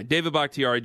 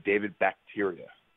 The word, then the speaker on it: Bakhtiari: What's the worst pronunciation you've ever heard, including the way I'm saying it? – The absolute worst wasn't even close. It was, and again, this was soccer. I remember they said David Bakhtiaria, and I was like, that's not even close.